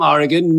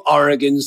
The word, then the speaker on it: Oregon: And she says, hey, I am Olivia from Oregon. New Oregons sometimes say Oregon, which sounds very L-O-T-R to me. And I wish that's how we pronounced it. But it's Oregon, not Oregon.